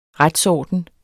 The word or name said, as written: RETSORDEN